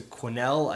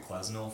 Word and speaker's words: Quesnel: Located real close to Quesnel, I think that's how you pronounce it, but I think we were wrong at one point if it was Quesnel or not. – Yeah, we were calling it Quesnel for a while, and that's not quite the right way to say it.